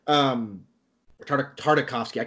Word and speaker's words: Tartakovsky: Fucking Tarkovsky, man. So, Tartakovsky. I cannot pronounce his name.